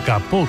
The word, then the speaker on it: capot: El alemán tomó prestado ese kaputt en el siglo XVII del francés capot, hoy pronunciado capó concretamente,